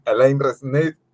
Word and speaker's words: Resnais: Alain Resnais. A ver, Jonathan, a ver. Alain René. Alain René. – Alain em, Acuérdate que, los franceses se pronuncian la R como si no pudiera pronunciarla bien.